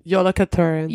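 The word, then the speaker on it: Jólakötturinn: All right, so Yule Cat, which is pronounced Yolakaturin? – Yolakaturin.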